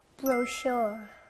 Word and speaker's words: brochure: 이 다음 단어도 슛 소리가 나요. 우리가 여행 가면 다양한 정보가 들어있는 책자를 받는데요. 이거는 영어로 뭐라고 배웠죠? – Brochure.